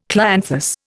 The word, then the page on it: clianthus: Indian